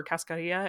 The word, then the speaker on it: cascarilla: There's a it's actually mostly, I believe, mostly linked to Santeria, but I know a lot of people do this, that cascarilla powder or cascarilla, I think is actually how it's pronounced, but I've heard a lot of, you know, white Midwestern people say cascarilla powder.